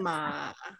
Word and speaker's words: Manorama: And my name is pronounced Manorama with the accent again on that last the O and the A. – Manorama.